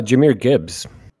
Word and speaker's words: Jahmyr Gibbs: Uh, I'm going to go with uh, Jahmyr Gibbs. – Uh, wrong. – Mm. wow. Micah. Did I, did I, say his name right? I don't even know if I said his name right.